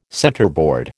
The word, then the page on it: centerboard: American English